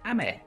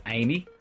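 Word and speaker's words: ame (雨): So you'd be tempted to pronounce it as Amy, but it's not. It's Japanese, so it's ame, ame.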